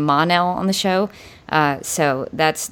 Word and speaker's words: Mon-El: Wilson that we've had on the podcast, and they also said the same thing about the Mon part of Mon-El being pronounced like Mun, like Monday, if you were to say Monday.